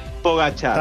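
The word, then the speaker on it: Pogačar: ¿Cómo dijo? Pogachar, dijo usted. – Así, más o menos. Tadej Pogachar.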